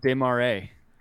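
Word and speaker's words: Des Moines: We need to find someone who's from, let's pick a random city, Des Maris. I definitely did not say that right. – Des Maris? – Des Maris? – Oh, that's pronounced Des Marais.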